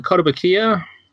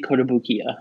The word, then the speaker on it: Kotobukiya: We go with Kotobukiya, although it could be Kotobukiya. I'm not sure about it. – Kotobukiya. – It's the emphasis on the E, not the I.